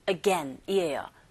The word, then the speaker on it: again: 이 again이라는 단어 발음하실 때 조심해야 될 것이 여러분 again이 아니라 again이에요. again. 그런데 이때 어라는 첫 소리 자체가 모음이죠. 근데 얘가 강세를 받지 않기 때문에 cloth라는 단어 뒤에 붙습니다.